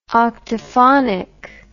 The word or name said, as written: octaphonic